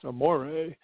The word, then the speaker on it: Amore: And if you didn't know it already, Amore, pronounced Amore, means love in Italian, which is our theme for tonight's podcast.